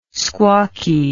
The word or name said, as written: squawky